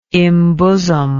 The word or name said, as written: imbosom